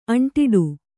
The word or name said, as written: ಅಂಟಿಡು